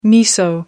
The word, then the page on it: miso: American English